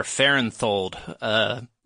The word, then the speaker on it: Farenthold: And then Blake Farenthold, or Farenthold, is how it should be pronounced.